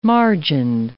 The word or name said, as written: margined